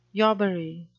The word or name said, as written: yobbery